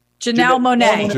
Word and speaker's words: Janelle Monáe: I'm going to say Janelle Monáe. Am I saying her name right? – Janelle Monáe.